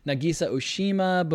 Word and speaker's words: Nagisa Oshima: Nagisa Oshima, boom boom boom. Yeah, that's how you say his name.